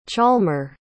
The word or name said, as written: chalmer